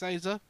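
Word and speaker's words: Asa: Is it Azza or Azza? – I think it's Azza.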